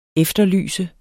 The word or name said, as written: efterlyse